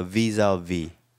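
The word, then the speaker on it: vis-à-vis: 스펠링 상으로야 vis-a-vis지만 프랑스 사람들은 요 첫 번째 것만 연결해서 읽으니까 visa. 끝에 거는 자음을 발음 안 하죠. 끝에 자음은 그러니까 vis-a-vis.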